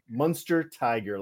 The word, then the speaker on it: Munsterteiger: Last name is pronounced Munster Tiger. Like Munster Tiger. – The cheese and the big cat.